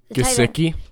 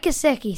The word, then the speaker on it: Gesicki: Gesicki? – Yeah, Mike Gesicki.